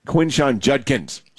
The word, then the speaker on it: Quinshon Judkins: But I do think that Quinshon Judson had some – Quinshon Judkins.